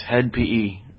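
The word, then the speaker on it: Hed PE: But you know, it's spelled H-E-D. With parentheses around it. I don't know if that actually means something other. – It just means bad grammar. – You know, but it's Head PE.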